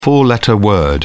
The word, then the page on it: four letter word: British English